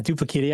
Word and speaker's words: Duplekita: And so for your most recent project, Duplekita, is that the right way to say it? How do we say it? – Yeah, Duplekita.